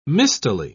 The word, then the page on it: mistily: British English